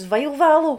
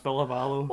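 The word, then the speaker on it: Ville Valo: Is that how you pronounce his name? – Villa Vallo, yeah. – I thought it was Vile Vallo. – No, it's Villa Vallo.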